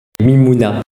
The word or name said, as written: mimouna